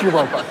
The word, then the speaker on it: Europa: Or it's Europa. Europa and Zeus had a little thing. – Okay. – Get back to where you guys were coming. So where you want to go to Europa? – Yeah.